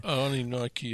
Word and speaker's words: Anunnaki: and I'm going to mispronounce this one, Anunnaki, is that correct? – Yeah, Anunnaki. Yeah.